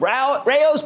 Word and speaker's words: Rao: Now that's the owner of Rao's Pasta Sauce, that's R-A-O, it's pronounced Rao, for roughly $2.7 billion in a deal that shows executives are no longer taking the merger-hating FTC that seriously.